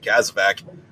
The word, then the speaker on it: CASEVAC: Like, again, my buddy Chuck, fucking, he had a line where, you know, he's talking about calling it a fucking Casvac, and he kept pronouncing it Casevac. And I was like, hey, man, why are you pronouncing this Casevac? And he's like, what is it? Oh, it's supposed to be Casvac.